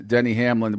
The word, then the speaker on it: Denny Hamlin: My hint to you would have been Denny Hamlin was obviously the correct answer. I would have said it rhymes with Jenny Lamlin.